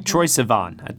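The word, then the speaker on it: Troye Sivan: I hope I'm saying his name right. Sivan. Mm-hmm. Troy Sivan. I think that's how you say it.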